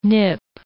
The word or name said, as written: nip